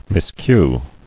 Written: Miscue

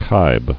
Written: Kibe

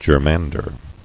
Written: Germander

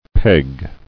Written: Pegging

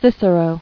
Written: Ciceronian

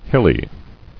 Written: Hilliness

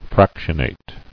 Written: Fractionate

